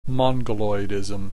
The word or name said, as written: mongoloidism